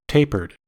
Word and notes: tapered: American English

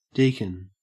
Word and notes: deacon: Australian English

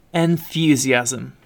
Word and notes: enthusiasm: British English

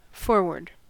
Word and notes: foreword: American English

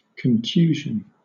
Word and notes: contusion: British English

